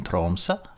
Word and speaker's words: Tromsø: The reason I really wanted to talk to Rahul was because we met in at a film festival in Norway, in Tromsø, and note my perfect Norwegian pronunciation,